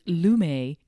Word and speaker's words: Lume: I use Lume deodorant, which is the one that you see. It's spelled L-U-M-E, so it is pronounced Lume because I hear him say it.